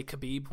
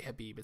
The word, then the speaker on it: Khabib: By the way, he hates it when people say Khabib, when people pronounce a K. It's supposed to be silent, not to be a stickler.